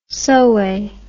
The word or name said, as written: Soay